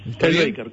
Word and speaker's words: Kyle Baker: Otro de los invitados de afuera es, mi pronunciación es horrible, Kyle Baker. – Kyle Baker, correcto, sí.